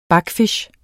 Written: backfisch

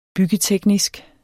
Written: byggeteknisk

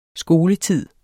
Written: skoletid

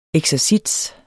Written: eksercits

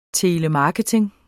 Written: telemarketing

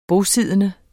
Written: bosiddende

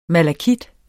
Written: malakit

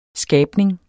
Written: skabning

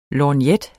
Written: lorgnet

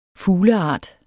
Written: fugleart

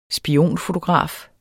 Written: spionfotograf

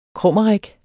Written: krummerik

